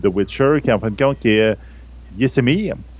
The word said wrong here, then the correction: Vesemir: Yasemir, je pense que ça, se prononce. Ben, non, Vesemir, excusez, Vesemir